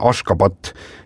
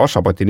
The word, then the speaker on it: Ashgabat: Ja selle pealinna tänapäevane õige kirjapilt ja hääldus on Ashgabat ja siis 1991. aastal nimetati siis paljud linnad ja asulad selles riigis ümber ja kui vanasti tunti seda ikkagi tõepoolest selle Ashgabati nimel, siis Ashgabat on tänaseks see nii-öelda nagu õige hääldus ja kirjapilt see S-il on mingisugune väike siuke konks all ja Ashgabat täpselt niimoodi ta ongi.